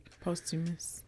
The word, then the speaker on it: posthumous: Obama gave him post, is it humus? How do you say it? – Posthumous.